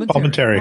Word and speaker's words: Palminteri: Palminteri.